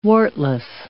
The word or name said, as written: wartless